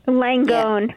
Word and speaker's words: Langone: Uh, Langon, am I pronouncing that right? Langon Health? Langone. Yeah. Langone Health,